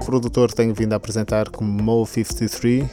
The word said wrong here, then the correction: Mouse: Enganei-me por causa da maneira como se escreve, mas é Mouse.